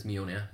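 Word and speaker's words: Mjölnir: Well, because as you just said, Mjolnir is, I think that's how you say it. Yeah. How are we saying it? – I always pronounce it as Mjolnir. – Like Mjolnir, yeah.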